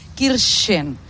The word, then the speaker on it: Kirchen: Sí, pero después fui al estadio del Schalke, y los stewards me dijeron, no, lo estás pronunciando mal porque es Kirchen.